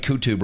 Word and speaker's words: Qutb: I think sometimes I pronounce it Qutby, but it's Qutb.